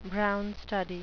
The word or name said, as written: brown study